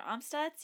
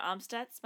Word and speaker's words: Amstutz: I'm Sandra Omstutz. You can find me on Twitter, Instagram, Letterboxd, all the others at Sandra Omstutz.